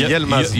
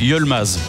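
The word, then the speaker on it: Yılmaz: Est-ce qu'on ne doit pas dire Yilmaz alors, puisque le I se prononce E. Donc exact. On a Y-I, donc c'est Yilmaz. – Yilmaz. – Yilmaz, pas Yel.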